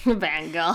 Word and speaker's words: Van Gogh: And that's so weird because it's like, you know, you can make prints of a Van Gogh. – Van Gogh. – I think that's the proper way to say it.